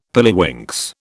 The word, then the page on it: pilliwinks: British English